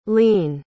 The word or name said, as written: leen